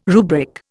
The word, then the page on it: rubric: Indian